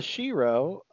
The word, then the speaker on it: shero: Instead of a hero, it's a shero, Gal Gadot for Wonder Woman. And greatest... – A what? – A Gal Gadot for shero. It rhymes with hero, you know.